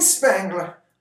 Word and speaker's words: Spangler: But Jean Spangler. I think. You know what though is funny is that her last name rhymes with Wrangler.